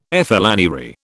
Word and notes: flânerie: British English

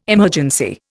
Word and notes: emergency: Indian

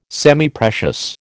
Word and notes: Semiprecious: American English

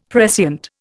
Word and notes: prescient: Indian

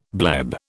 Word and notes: bleb: British English

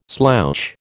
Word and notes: slouch: American English

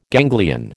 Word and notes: ganglion: American English

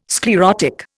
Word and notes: sclerotic: Indian

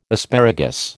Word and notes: asparagus: American English

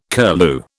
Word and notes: curlew: British English

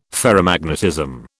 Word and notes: ferromagnetism: British English

